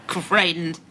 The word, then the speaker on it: Greitens: Greitens, back from, he's horrible. And Greitens, right, the revenge porn guy.